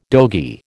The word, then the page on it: dogie: American English